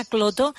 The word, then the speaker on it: Klotho: Pues me apunto esta proteína, la proteína cloto, que nos has descubierto hoy, porque me da la sensación de que vamos a seguir hablando de ella, ¿no, Carlota? – Sí, sí, sí. Y se escribe K-L-O-T-H-O.